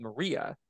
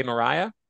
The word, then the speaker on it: Maria: And I was spelling them differently because in the book, they're spelled like in England, you guys see Maria like West Side Story of Maria, but you say Mariah with an, but in America, if it doesn't have an H, it's Maria.